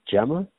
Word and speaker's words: gemma: I did want to ask you if you know about these, looking into that, the West Fern species, I came across, I guess it's pronounced Gemma. – Yeah. – G-E-M-M-A.